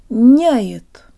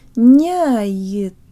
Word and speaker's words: объединяет: Няет. Няет. – Notice that the Y sounds like an I-flavored schwa. – Няет. – And the te is pronounced with the part of the tongue behind the tip.